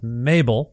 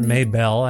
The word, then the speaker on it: Maybel: Maybel is how you say it, not Maybel.